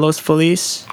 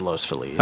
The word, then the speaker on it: Los Feliz: Proper Spanish would be Los Feliz, but everyone out here says Los Feliz.